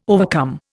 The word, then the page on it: overcome: Indian